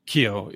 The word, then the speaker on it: Keough: It's Scott Keo, right? – Keo, yeah. K-E-O-U-G-H.